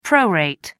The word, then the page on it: prorate: American English